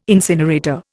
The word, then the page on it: incinerator: Indian